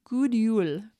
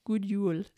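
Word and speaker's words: God Jul: And then you can tell people, let's see, I always have to stop and think about how to say it because it's spelled G-O-D. So it looks like God. And then I'm like, good. And then I'm thinking, okay, it's neither one of those. It's good. Good Yule. Good Yule.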